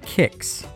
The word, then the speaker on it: Qix: By the way, almost every resource I could find about today's game since its inception in 1981 as an arcade cabinet indicates that the first letter of the game's name should be pronounced with a K sound, not a Q sound. So I believe that it should be pronounced QiX.